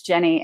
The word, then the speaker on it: Jennie: Just search for Coach Jennie and make sure you spell it with the IE, Coach Jennie, and you'll find me on everything.